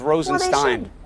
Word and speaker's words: Rosenstein: People can read it for himself, and his name is Rosenstein, by the way.